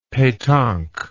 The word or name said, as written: petanque